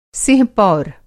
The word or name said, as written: सिंह-पौर